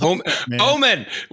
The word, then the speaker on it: Omen: Arcs of Oman going up Oman, sorry, going up. – Omen. Well, you pronounced it wrong twice.